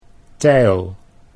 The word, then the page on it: teil: Dutch